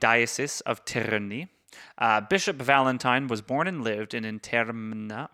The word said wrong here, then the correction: Interamna: I can't pronounce that. Interamna. – Interamna.